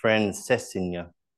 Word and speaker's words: Francesinha: Francesinha.